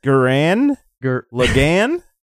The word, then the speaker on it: Gurren Lagann: Gurren Lagann, no, right? That's the pronunciation. Lagan. – Gundam no, Lagoon. – Gurren Lagann? That's how you say it.